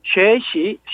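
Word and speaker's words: 쉐시 (学习): 오늘은 중국어 공부 좋다, 중국어 공부 재미있다라는 식으로 해갖고 학습 중문인데 공부하다, 학습하다, 배우다를 중국어로 학습이라고 합니다. 영어 아 우리말 한자 학습을 써가지고 쉐시 쉐시라고 읽고요.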